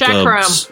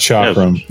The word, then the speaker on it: chakram: The phoenix circles are one form of charcam. A charcam is a round weapon, kind of like chakram. – A... Yeah, it's pronounced chakram.